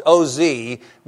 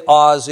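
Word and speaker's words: Oz (עוז): You can spell it like Oz. You know that wizard guy, the Wizard of Oz, O-Z, but we don't say Oz in Hebrew, we say Oz.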